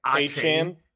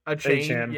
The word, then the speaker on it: Achane: I don't know how to say his last name, like Achane. – Achane.